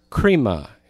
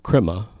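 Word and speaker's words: krima (κρίμα): This is the word krima. K-R-I-M-A. It's the word we get crime from in English. If you're Elvis, you pronounce it crima, like Mary Crima, or I'll have a blue crima without you.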